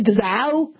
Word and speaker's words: Dzau: This is Professor Victor Dzau from Duke University Medical Center. And that's how you pronounce it, I believe, Dzau.